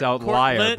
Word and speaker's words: outlier: It's also not outliner. It's outlier.